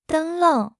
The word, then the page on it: 灯笼: 0.8x